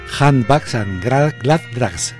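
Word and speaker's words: Handbags and Gladrags: Difícil de pronunciar. A ver, lo intento otra vez. Handbags and Grandbags. Correcto.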